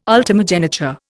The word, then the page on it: ultimogeniture: Indian